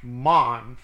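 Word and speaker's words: mon (מָן): Verse 12, and the man, that's spelled like man, M-A-N, but it's pronounced mon, 4478.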